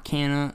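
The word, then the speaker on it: Canha: They just newly added Mark Canaha, or whatever you want to say his name. – Mark Canna.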